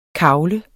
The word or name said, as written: KAGLEDE